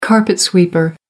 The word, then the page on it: carpet sweeper: American English